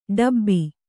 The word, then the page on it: ಡೆಬ್ಬೆ: kannada